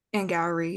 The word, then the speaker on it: Angourie: And Gowrie, yeah, we had to look it up.